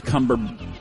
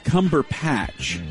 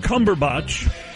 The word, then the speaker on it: Cumberbatch: What'd you call him? Cumberbatch? – Cumberpatch.